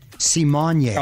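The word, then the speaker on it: Symoné: She says it's actually pronounced Simone.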